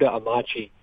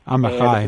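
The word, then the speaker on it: Amichai: He's an Israeli poet named, I'm going to mispronounce his name, but Yehuda Amachi. – Amachai.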